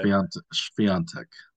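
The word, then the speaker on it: Świątek: So okay. Swiatek. – Yeah, that's I'd heard it pronounced that way, and But like repeatedly heard it pronounced that way, so I just assumed that is how it was. – You're right. Yeah, I'm sure that's right.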